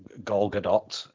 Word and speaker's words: Gal Gadot: Like, I mean, we can talk a little bit later about Gal Gadot. I believe is the correct pronunciation, and my intensely and rapidly growing distaste for that woman.